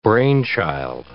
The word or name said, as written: brainchild